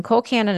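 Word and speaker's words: Colcannon: And Cole Cannon is pronounced Cole Cannon.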